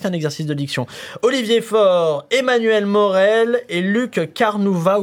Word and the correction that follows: Luc Carvounas: On va l'appeler Luc Carvounas.